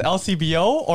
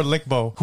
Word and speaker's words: LCBO: Yo, do you pronounce LCBO or Licbo? – Who the f*** is Licbo?